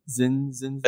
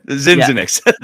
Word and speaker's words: Zinzinix: This is going to sound familiar to you because I'm pretty sure Zin, that's how you, how do you pronounce his fucking name? – Zinzinix. Yeah. – Okay. – Zinzinix.